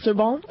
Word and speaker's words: Sorbonne: She graduated from Sorbonne. I hope I am saying that correctly. – Sorbonne, okay.